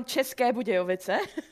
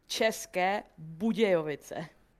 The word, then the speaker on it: České Budějovice: Okay, from České Budějovice. – One more time. – České Budějovice. – České Budějovice. – Almost. – Got him.